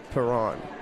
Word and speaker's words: Perron: So I'm just going to come right out and ask you, is it Perron or Perron? – I mean, most of the people say Perron.